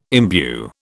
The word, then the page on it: imbue: British English